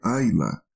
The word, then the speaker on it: Islay: E a região clássica que produz esses whiskies na Escócia é uma ilha chamada Islay, que se pronuncia Ayla